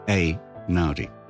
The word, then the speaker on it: Einaudi: If it's E-I in Italian, it should be Einaudi.